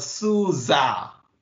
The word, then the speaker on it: SUSE: I used to say SUSE, but then I met a person from SUSE and he showed me a video of how to pronounce it correctly. And it's called SUSA.